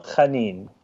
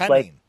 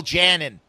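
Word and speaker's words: Mikel Janín: It's Mikhail Chanin. It's Chanin. Sure. – Like a little Hebrewish Chanin. – I'm with you. No, okay. Yeah, I always want to say it properly. So Michael Janin.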